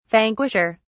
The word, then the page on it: vanquisher: British English